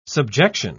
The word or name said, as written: subjection